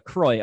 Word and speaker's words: Croix: But Croix. Okay. All right. – Yeah, we have a huge contingency of retail partners up in Canada, and everybody up there pronounces it as if they were from France.